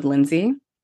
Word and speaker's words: Linzy: So when it's a promo code, I'm like, okay, it's L I N Z Y, no D.